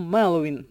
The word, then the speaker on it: Меловін: Костянтин Бочаров часто просить журналістів та шанувальників називати його лише псевдонімом Меловін. І просить ставити наголос правильно. На перший склад.